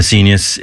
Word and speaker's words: Seignius: It's I'm an S-E-I-G-N-U-S. I'm a Senius. It rhymes with genius. I'm an S-E-I-G-N-I-U-S.